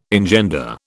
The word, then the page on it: engender: British English